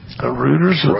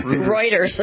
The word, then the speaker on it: Reuters: Apparently, according to Reuters, Reuters. – How many times do I say that wrong? – You say that wrong every time. – Just a couple of hundred, yeah. – Reuters.